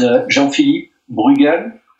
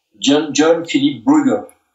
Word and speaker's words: Jean-Philippe Bruegel: My name is Jean-Philippe Bruegel. This is a French pronunciation. Jean-Philippe Bruegel.